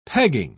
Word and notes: pegging: British English

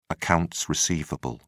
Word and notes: accounts receivable: British English

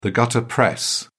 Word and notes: gutter press: British English

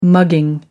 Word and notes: mugging: American English

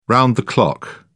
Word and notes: round-the-clock: British English

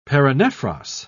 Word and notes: paranephros: British English

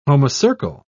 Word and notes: homocercal: British English

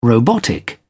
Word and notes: robotic: British English

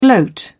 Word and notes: gloat: British English

